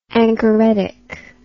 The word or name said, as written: anchoretic